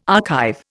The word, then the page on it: archive: Indian